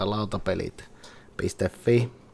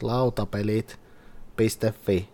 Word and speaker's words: Lautapelit.fi: It's designed by Touko Tahkokallio, and artists are Noah Adelman, Jere Kasanen, Jukka Rajaniemi, and Sampo Sikia, and published by Lautapelit.fi. That's the way how you pronounce that, all of you, if anyone listens. It's Lautapelit.fi or .fi, and it's Touko Tahkokallio.